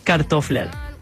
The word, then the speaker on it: kartofler: En danés, papas se dice kartofler.